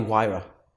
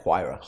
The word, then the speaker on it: Huayra: That's a Pagani Huayra. – Oh, is that how you say it? – Yeah, Huayra. – Huayra. – Yeah, yeah, yeah. Yeah, take some learning.